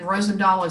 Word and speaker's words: Rosendahl: and Rosendahl is R O S as in Sam, E as in Nancy, D as in dog, A H L.